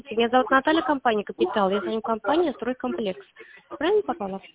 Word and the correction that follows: Стройкомплекс: «Стройкомплекс» вы попали. – А? – «Стройкомплекс» вы попали. – А, «Стройкомплекс». Неправильно ударение поставила, извините, пожалуйста.